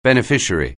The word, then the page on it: beneficiary: anglais